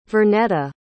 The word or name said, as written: vernetta